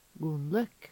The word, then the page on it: Gonlekk: Numedalsmål